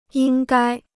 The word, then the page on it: 应该: yīng gāi